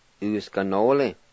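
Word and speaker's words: usganoli: Number four, slow, uskanole.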